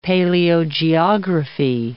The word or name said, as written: paleogeography